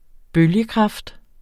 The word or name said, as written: bølgekraft